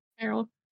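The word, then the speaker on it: Ferrell: Conchata Farrell. – Farrell.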